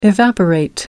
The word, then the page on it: evaporate: American English